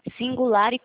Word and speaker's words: Singulare: Singular e corretora. – Vingular. – Singulare. É como se estivesse escrevendo Singular. – singular. Isso, singular, só que daí coloca o E no final.